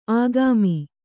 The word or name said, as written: ಆಗಾಮಿ